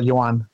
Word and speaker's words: Yoan: How do you pronounce his first name? – Yoan. Yoan. Kind of like Johan, but Yoan.